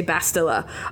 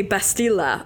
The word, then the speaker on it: Bastila: No, Knights of the Old Republic, where it was an RPG game where you were there was Carth Onasi, Bastila Shan, which I can't believe there are people who don't say Bastila. I heard someone say Bastila. I was like, no, it's Bastila. That's how they say it in the game.